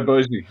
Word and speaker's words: Bossi: Well, it depends in Italian. It's Ricardo Umberto Guinino Bossi, but for the Australians, it's Ricardo Bosi.